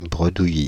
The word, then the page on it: bredouillis: France (Île-de-France); /bʁə.du.ji/